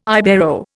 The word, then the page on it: Ibero: Indian